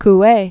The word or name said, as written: Emile Coue